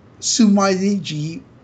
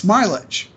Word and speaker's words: Smilage: So for me, it's not Sumaiji, it's Smilage, because even in their first promotional video, they're writing in English letters Smilage on the blackboard. So it's Smilage, because it's in English.